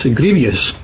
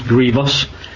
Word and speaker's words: grievous: They're not grievous, or as often mispronounced, grievous. There's no such word as grievous, by the way, it's grievous.